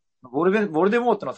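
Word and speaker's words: Voldemort: ボルデモートの最後の T は無声なんだって。 – へえ。 – オーディオブックでは。 – はいはい。 – ボルデモーになるのかな。 – うんうん。 – でも、映画だと T は発音されてるんですよ。で、ローリング氏に聞いたら、まあ、無声の T が正しいと認めたらしいんですよね。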